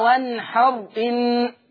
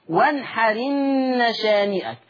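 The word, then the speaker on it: وانحر إن: وانحرن مش بقى وانحر ان، لا، شيل الهمزة، انقل الحركة بتاعتها للحرف الساكن اللي قبل منها وانحرن شانئك.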